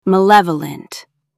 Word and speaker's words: malevolent: Malevolent. Ma. Lev. A. Lunt. Meaning, spiteful, wicked, and actively wishing harm on people. Flip bene, good, to male, bad, literally wishing evil. Think of classic villains with malevolent smiles. Stress the second syllable.